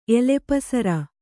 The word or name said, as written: ಎಲೆಪಸರ